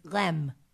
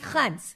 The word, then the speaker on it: Reims: So it's spelled R-E-I-M-S. So I thought in French it was Rem, but it's not. The way they pronounce it is Reims. It's like an H in the front and an N instead of an M.